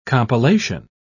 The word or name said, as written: compilation